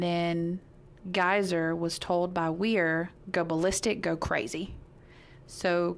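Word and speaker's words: Weier: It's W-E-I-E-R. – Weir. – Weir. That's Weir. How you say it.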